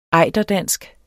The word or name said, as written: ejderdansk